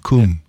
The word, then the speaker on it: come: The people who are from the Midlands and farther north in England, they don't say "come" with that O sound. It's like a U sound, "cum."